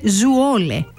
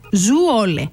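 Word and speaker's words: Zwolle: ότι η πόλη προφέρεται Ζουόλε. Ζουόλε.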